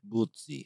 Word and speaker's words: Butzi: Butzi It's spelled B-U-T-Z-I. – Bootsy. – Bootsy.